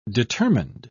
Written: determined

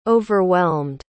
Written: overwhelmed

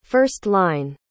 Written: first-line

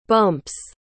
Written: bumps

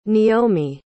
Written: neomi

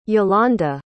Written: yolanda

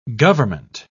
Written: gov't

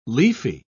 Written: leafy

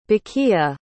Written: baqia

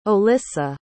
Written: Olisa

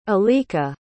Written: alika

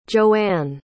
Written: joanne

